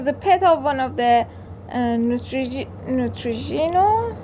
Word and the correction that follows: Neutrino: Neutrino. you were getting good at the pronunciation, and then you went back to Neutrogena.